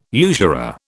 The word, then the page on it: usurer: British English